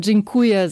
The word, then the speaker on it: Dziękuję: Schon wenn man Dziękuję sagt, da ist schon auch ein Aun drin, also mittendrin und hinten auch. Und ja, das muss man auch schon anders aussprechen.